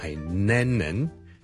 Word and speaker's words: Lennon: 第一个字呢就係、是、人名嚟嘅 ,John Lennon。 John Lennon。 John Lennon, 一个英国嘅好出名嘅歌手作曲家啦。 Lennon。 L-E-N-N-O-N。第一个是 L 音，所以不要将它读成 N 音，不是 Lennon，是 Lennon， Len， Len，后面那个才是 N 音，